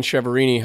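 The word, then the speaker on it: Chiaverini: It's just my name, Ryan Cheverini.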